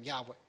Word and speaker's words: Yahweh: is that the right pronunciation? He says, yes. He says, that's how you're to pronounce the name, Yahweh. We didn't say Yahweh, he says, that's how you'd pronounce it.